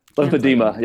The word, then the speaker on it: lymphedema: Lymphedema, yeah. – Lymphedema. – People always add the extra syllable to it, like there's an I, like demia. I'm like, no, it's just lymphedema.